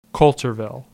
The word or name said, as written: Coulterville